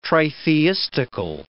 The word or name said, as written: tritheistical